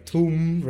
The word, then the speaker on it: Tomb: Tomb Raider. Tecnicamente Tomb, si legge Tomb invece che Tomb, come diciamo, però è stranissimo quello così. – Però Tomb è più simile a Bomb che più simile a Boobs, quindi...